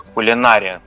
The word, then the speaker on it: кулинария: То есть вот как раз поварское искусство, кулинария, да, правильно ставить, угу, ударение, угу, на букву А, оно и предполагает умение и владение, создание некоторой гармонии.